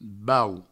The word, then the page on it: bauc: Provençal; baus